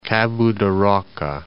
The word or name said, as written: Cabo da Roca